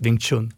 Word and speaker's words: Wing Chun: Jag började också med judo och sen så blev det eh, lite, eh, jag prövade på någon Wing Sun och jag vet inte ens hur det uttalas, Wing Chun.